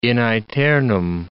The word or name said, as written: in aeternum